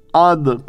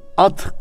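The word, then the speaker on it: Âd: Ve ila adin ahahum huda biz Ad kavmine de Ad Arapçada elifin çekisi ayının çekilmesiyle okunuyor. At kavmi değil yani.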